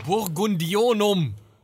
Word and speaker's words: Burgundionum: Genau, das Lex Burgundonium. Ähm, Habe ich es richtig ausgesprochen? Burgundionum! Äh, Burgundionum, Lex Burgundionum ähm, finden sich auch Verweise ähm, auf Personen, die man mit König Gunther, Gernot und Giselher verknüpfen könnte.